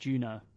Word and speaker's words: Juneau: The city and borough of Juneau is pronounced Juno.